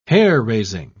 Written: hair-raising

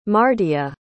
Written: mardea

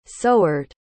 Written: sowered

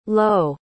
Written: loewe

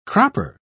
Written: cropper

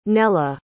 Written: nella

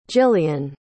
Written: gillian

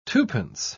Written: twopence

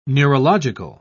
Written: neurological

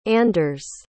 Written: anders

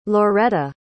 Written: loreta

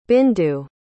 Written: bindu

